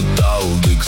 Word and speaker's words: Талдык: Это Талдык, слышишь? Ударение на первый слог.